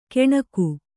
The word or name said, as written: ಕೆಣಕು